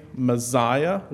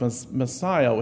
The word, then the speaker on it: Messiah: Now, just a note this week, I tend to naturally say Messiah with a Z. But I was told I was crazy for saying it that way. It's pronounced Messiah with an S.